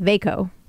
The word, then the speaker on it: Veikko: So my family is Finnish, and I wanted to give him a name after my grandfather, Veikko, or Veikko, that's how it's pronounced, Veikko.